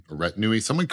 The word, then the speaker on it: retinue: So her and her retune walk in. Retinue. Someone corrected me on Spotify. I was pronouncing that one wrong. And I still am going with retune.